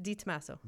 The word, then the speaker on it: DiTomaso: DiTomaso.